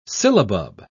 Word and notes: sillabub: British English

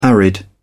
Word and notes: arid: British English

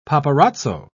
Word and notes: paparazzo: British English

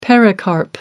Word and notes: pericarp: American English

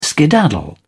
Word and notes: skedaddle: British English

